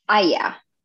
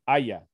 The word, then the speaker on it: Aya: Is the stress on the last letter. So Aya for the father? – Aya. – Okay. Aya. – Yeah. Equal stress.